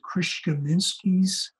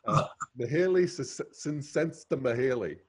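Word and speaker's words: Mihaly Csikszentmihalyi: Did I say his name correctly? – Mihaly is, I used to practice it. I'm not sure I got it right, but Mihaly, Csikszentmihalyi.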